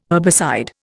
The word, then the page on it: herbicide: Indian